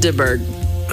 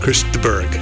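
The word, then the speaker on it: de Burgh: DeBerg. – Chris DeBerg. Thank you. – DeBerg. – Yeah. – DeBerg. – I hardly know. – Chris DeBerg.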